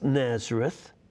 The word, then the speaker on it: Nazareth: scholars didn't know how to pronounce Nazareth because in Hebrew, there are a couple of Z sounds, two Z sounds. One would be Nazoret, and that would come from the word to take a vow, Nazar.